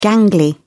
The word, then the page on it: gangly: British English